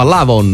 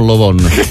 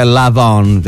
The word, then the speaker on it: Lavon: Sì, doveva fare un pezzo che si chiama Lavon, non Lovon. No, che poi se lo pronunci in inglese è Lavon, effettivamente, che sembra un'altra roba.